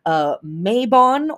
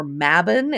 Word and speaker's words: Mabon: This is going to be Mabon, or Mabon. It's pronounced both ways.